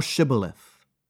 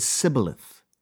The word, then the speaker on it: Shibboleth: Then said they unto him, Say now Shibboleth. And he said Sibboleth, for he could not frame to pronounce it right.